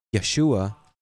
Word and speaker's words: Yeshua: Yeshua, Mashiach, would be in the Hebrew pronunciation.